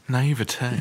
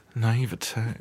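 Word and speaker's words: naïveté: So Audrey dies from her naivety, or her I can't say words nativity? This week. – She Whatever. – Dies at Christmas. – She's naive. – Here, I'll pronounce it for you. Naivete.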